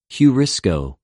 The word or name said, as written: εὑρισκω